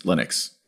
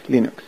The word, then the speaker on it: Linux: Linux. – Hello, this is Linus Torvalds, and I pronounce Linux as Linux.